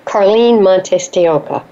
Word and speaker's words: Carlyn Montes De Oca: Carlyn Montes De Oca.